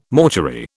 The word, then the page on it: mortuary: British English